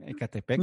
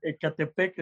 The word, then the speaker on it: Ecatepec: Espero que haya pronunciado bien Ecatepec. – Mm, mm, lo dijiste súper bien. Ecatepec está.